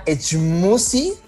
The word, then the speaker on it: Schmooze: La aplicación se llama Echmusi. Es muy, muy rara. Se escribe S-C-H-M-O-O-Z-E. Echmusi.